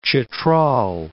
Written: Chitral

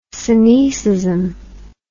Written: synoecism

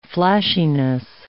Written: flashiness

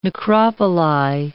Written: necropoli

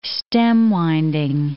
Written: stem-winding

